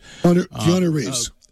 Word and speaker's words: Keanu Reeves: I think of Kenal the Matrix guy, Kenal Reeves, right? Am I saying his name right? Kenal Reeves? – Keanu Reeves. – Keanu. Yeah. Keanu Reeves,